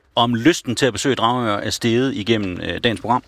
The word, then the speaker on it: Dragør: De skriver, at vi udtaler Dragør forkert. – Dragør. – Men jeg mener altså, det er Dragør.